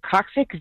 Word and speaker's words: coccyx: And I caught on a little lip and broke is it coccyx? Is that how you pronounce it? – Yeah, sure.